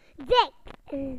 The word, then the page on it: thick: British English